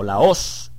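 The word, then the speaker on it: laos (λαός): encontramos en primer lugar el término laos o laos. Aquí aparece con acento en la O en el diccionario Strong.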